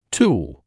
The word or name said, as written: tool